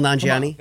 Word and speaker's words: Nanjiani: Kumail Nanjiani.